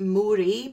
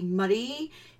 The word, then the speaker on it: Moray: So if you're not from Scotland and you like to pronounce things wrong, you might pronounce it Moray by mistake, but it's actually Moray, in Moray Council area, and it says it's in near Ballandalich.